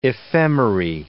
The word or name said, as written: ephemerae